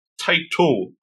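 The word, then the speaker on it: Tite: It looks like it's Taiti with the E. – Yeah. – It's Taito, apparently. Taito Kubo.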